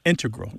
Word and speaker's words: integral: Just to clarify here, both correct, but the mathematicians and math-using scientists are more likely to integral with the stress on the first syllable.